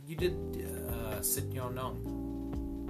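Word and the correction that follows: Sityodtong: Shout out to Chatri Sid Yaw Tong again.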